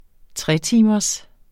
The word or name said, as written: tretimers